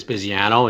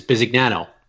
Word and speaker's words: Bisignano: Sicily is Bisignano, and in the States is Bisignano.